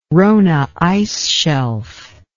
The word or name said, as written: Ronne Ice Shelf